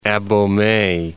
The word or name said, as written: Abomey